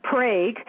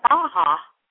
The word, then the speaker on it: Praha: I was saying Prague, and you said, well, as in America, we pronounce it Prague, but there in Czechoslovakia, they pronounce it how? – Praha. – Praha. Okay, so like our Baja.